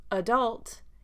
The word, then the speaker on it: adult: So the main difference here with the word adult in American English is the first A has the schwa a sound. Adult. Adult. And that's because the emphasis, the stress, is on the D-U-L-T, the second syllable. Adult. So that is where my intonation rises, my pitch rises up to show stress on that syllable. Adult. Because the first A is non-stressed, it takes the schwa sound, the unstressed syllable sound.